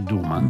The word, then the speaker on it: Duma: Conferma che Duma si legge così come è scritto e vuol dire accendi. – Come in siciliano. – Sì, Duma. – Esatto. – Duma. – Quando la doppia D è presente in mezzo alla parola si legge alla siciliana.